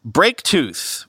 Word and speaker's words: BrakTooth: Researchers have uncovered Braketooth, which I believe is pronounced that way, even though it's spelled B-R-A-K-T-O-O-T-H,